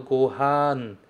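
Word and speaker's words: gohan (ご飯): Eu falo gohan, gohan. Ha, depois n. Gohan, gohan o tabemasu. Sempre com ele mais aberto e depois você vai e ataca com o N, tá bom?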